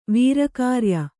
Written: ವೀರಕಾರ್ಯ

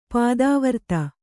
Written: ಪತಿವ್ರತೆ

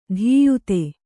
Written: ಧೀಯುತೆ

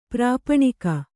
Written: ಪ್ರಾಪಣಿಕ

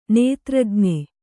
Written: ನೇತ್ರಜ್ಞೆ

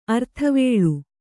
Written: ಅರ್ಥವೇಳು

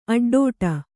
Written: ಅಡ್ಡಡಿ

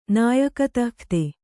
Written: ನಾಯಕತಃಖ್ತೆ

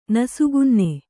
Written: ನಸುಕುನ್ನಿ

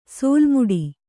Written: ಸೋಲುಮುಡಿ

